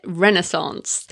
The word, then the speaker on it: Renaissance: I think that's how Americans say it, Renaissance. So when I got your tweet, Edward, I was like, that's really funny because I didn't even know Americans pronounce it differently.